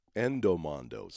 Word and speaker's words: Endomondo: Edamondo. Endomondo. Sorry, I was saying it wrong. Endomondo. There.